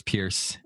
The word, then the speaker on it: Peirce: Oliver Wendell Holmes, William James, Charles Sanders Peirce. And then there were a couple other people that were sort of in and out. – It's spelled P-E-I-R-C-E. – Yes. – Peirce. – Yeah. Peirce. Okay.